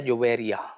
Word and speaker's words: جویریا: اچھا، اس کا پرونسیشن لوگ صحیح نہیں کرتے، جویریا پڑھتے ہیں، جویریا کہتے ہیں، جویریا. – ہاں، تو یہ لفظ ہے جویریا. – صحیح، تلفظ اس کا یہ ہے.